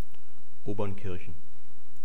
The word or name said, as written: obernkirchen